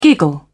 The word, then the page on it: giggle: British English